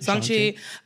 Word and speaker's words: Shang-Chi: We skipped The Incredible Hulk, Iron Man 3, Thor Dark World, Guardians of the Galaxy, Ant-Man and the Wasp, Black Widow, Shang-Chi or Song-Chi, I don't know how to pronounce it. – Shang-Chi.